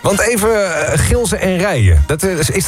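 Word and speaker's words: Gilze en Rijen: En het is echt officieel Gilsen en Rijen. Zo moeten we het uitspreken. – Ja, Gilsen en Rijen.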